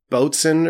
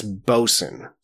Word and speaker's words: boatswain: What is spelled as boatswain, as in a boatswain's mate, is not... – Is it bosun? – It's not pronounced like that at all. I think I said boatswain or something. It's bosun. So that's a bosun's mate, just so you know.